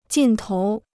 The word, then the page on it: 劲头: jìn tóu